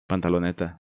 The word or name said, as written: Pantaloneta